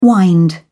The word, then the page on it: wind up: British English